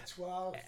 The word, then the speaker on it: Étoile: What is it? – Étoile. – Étoile. – French for stars.